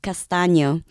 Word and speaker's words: Castaño: Isn't there a more beautifully ethnic way of saying it? – Yes. In Spanish, my last name is Castaño.